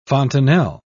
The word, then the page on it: fontanelle: British English